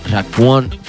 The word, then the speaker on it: Raekwon: Nunca lo sé pronunciar muy bien porque es como una A y una E. Raekwon.